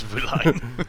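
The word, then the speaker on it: Vilain: It's Villain. – It's Villain. It's French.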